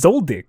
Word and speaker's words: Zoldyck: No, it's Zoldyck. That's how they pronounce it.